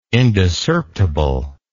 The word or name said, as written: indiscerptible